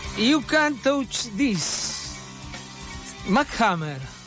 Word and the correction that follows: MC Hammer: Sí. Sí. MC Hammer. – ¿Pronuncié bien? – MC Hammer.